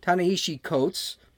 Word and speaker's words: Ta-Nehisi Coates: So they had a bunch of pro-reparations folks, like that hack writer, Ta-Nehisi, I can never pronounce his name, Ta-Nehisi Coates.